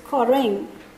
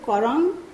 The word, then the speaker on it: koreng: Okay, so you get this word, which is koreng. Now you see, there's a variation. There was a speaker who said korang, and that's given in the Great Andamanese script, and another speaker which pronounced is koroin, again given in Great Andamanese script.